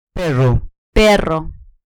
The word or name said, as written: perro